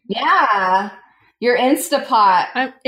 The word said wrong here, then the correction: Instant Pot: I always say it wrong. I want to say it like Instagram, but it's Instant Pot.